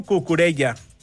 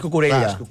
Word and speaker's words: Cucurella: I et diré, podria passar, Et diré una altra cosa que està passant a Catalunya, que hi ha una febre per no pronunciar la ella. – Clar. – A Catalunya molta gent diu Cucurella. – I és Cucurella.